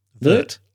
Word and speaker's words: Wirth: Wirt. – Yes. Wirt. That's great. Correct pronunciation. – it's spelled W-I-R-T-H, but it's pronounced Wirt.